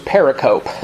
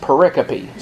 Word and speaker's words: pericope: By the way, a pericope, it's the word, it looks like, I used to think it was pericope. I used to think somebody was trying to spell the word periscope, but they were spelling it wrong. But it's actually pronounced pericope.